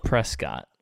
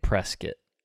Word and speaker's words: Prescott: But so it's spelled Prescott, but the people there say Prescott.